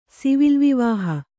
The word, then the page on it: ಸಿವಿಲ್‍ವಿವಾಹ: sivil vivāha